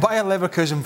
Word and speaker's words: Bayer Leverkusen: You've got Bayer Leverkusen, well pronounced. Yep. You've got Bayer Leverkusen flying.